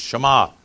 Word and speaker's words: Shema: Sometimes we say the Shema, but I've researched again this week, and there's no, you don't say the E, just Shema, or Shema, I said it wrong anyway.